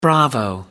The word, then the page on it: bravo: American English